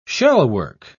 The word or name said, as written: shellwork